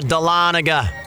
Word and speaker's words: Dahlonega: Yes, a little dive bar in Dongola. – No, Dahlonega. – Oh. – It took me a while to know how to pronounce it, but it's in Georgia.